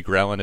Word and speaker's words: ghrelin: I know leptin and, how do we say this, ghrelin? – Ghrelin. – Ghrelin, thank you.